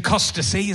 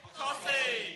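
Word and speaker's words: Costessey: That was in Costacy. Is that how you say Costacy? Whoa, fuck no. How do you say it? – Cossy. – Cossy, okay. What? There's a fucking T and E, Dad.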